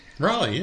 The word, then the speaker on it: Raleigh: Is it Raleigh? – Raleigh, yeah. Raleigh, North Carolina.